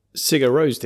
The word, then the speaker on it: Sigur Rós: Yeah, it's what Sigur Rós did, notice the pronunciation, with their Hopelandic.